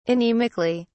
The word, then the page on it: anemically: British English